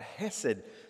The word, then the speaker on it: hesed: But remember, hesed, that's exactly right, hesed. Sometimes you see it H-E-S-E-D, sometimes C-H-S-E-D, because in the Hebrew, the C-H is pronounced many times like the H. So hesed, H-E-S-E-D or C-H-S-E-D.